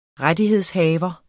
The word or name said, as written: rettighedshaver